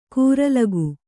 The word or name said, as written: ಕೂರಲಗು